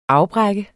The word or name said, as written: afbrække